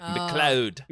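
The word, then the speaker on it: MacLeod: Yes, I spelt it McCloud in the notes because that's how it's pronounced, but it is Scottish, so his name is like McLeod, like McCloud.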